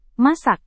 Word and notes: masak: Indonesian

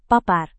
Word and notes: papar: Indonesian

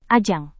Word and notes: ajang: indonesian

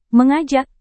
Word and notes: mengajak: Indonesian

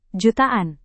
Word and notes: jutaan: Indonesian

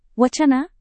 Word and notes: wacana: Indonesian

